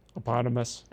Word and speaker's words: eponymous: But it's a moot point because Jack name checks the eponymous? How do you say that? – That was pretty close. Eponymous. – Eponymous, okay. – Yeah, think like hippopotamus.